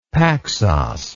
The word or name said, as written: paxos